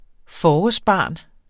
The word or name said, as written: forårsbarn